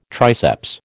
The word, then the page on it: triceps: American English